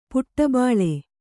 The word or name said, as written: ಪುಟಬಾಳೆ